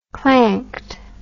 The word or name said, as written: clanked